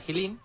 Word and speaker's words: Jilin: Para pronunciar Jilin, imaginaos la palabra jamón. Se pronuncia exactamente igual.